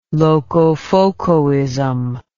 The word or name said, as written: locofocoism